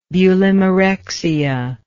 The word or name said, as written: bulimarexia